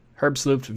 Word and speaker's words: Herbstluft: And by the way, I say Herbstluft VM, not WM, because it's a German word or a German project, and the name is German, so the pronunciation is VM.